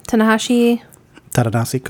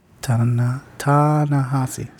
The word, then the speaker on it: Ta-Nehisi: Ta-Nehisi Coates. You're not saying it right either. – Yeah. – There's not two N's in there. – Ta-Nehisi. Sometimes I say it fast enough.